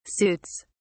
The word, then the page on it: suits: British English